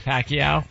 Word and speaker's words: Pacquiao: Pacquiao.